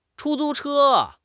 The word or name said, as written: chu1 zu1 che1